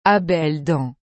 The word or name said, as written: A belles dents